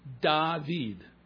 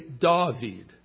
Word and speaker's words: David: If you're a Sephardic Jew, and you speak in that Sephardic dialect, you will say David, David, like father, father, David. If you're an Ashkenazic pronouncer, you'll say David, David, like saw, saw David.